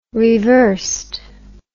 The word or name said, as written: reversed